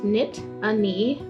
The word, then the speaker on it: Nittany: The origin of the name Nittany is derived from the Native American words loosely pronounced Nit-a-nee, a reference to the single mountain that provided a protective barrier from the elements for the indigenous people of the region.